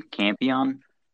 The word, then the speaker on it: Campion: Jane Campion? It looks like Champion, I was going to pronounce it like Champion without an H.